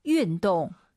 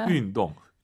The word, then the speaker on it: 运动: 자, 발음하실 때, 입을 가로로 벌려서 win, 우리가 흔히 알고 있는 그 영어의 win, you win 할때그 win 발음이 아니에요, 사실은. 운동은 어렵게 발음하셔야 돼요. 운동이 어렵잖아요. YU 발음은 입술을 동그랗게 말아서 운동 이거예요.